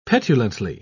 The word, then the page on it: petulantly: British English